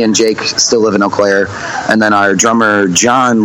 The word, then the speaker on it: Eau Claire: How do you say Eau Claire or Eau Claire? – It's Eau Claire. So okay. I think in French it means clear water.